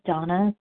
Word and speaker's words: Dana: This is Donna B. My first name is spelled D-A-N-A.